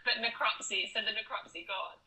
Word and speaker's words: necropsy: Well, you'll be happy to know that the I have to repronounce this. It's necropsy. – There we go. Yeah. – Was still able to be performed. Necropsy. That sounds better. – Yeah. I think it's necropsy. It's also like Flopsy, the elephant that got killed.